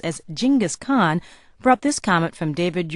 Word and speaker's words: Genghis Khan: The correct, that is, Mongolian pronunciation of the name is Genghis Khan.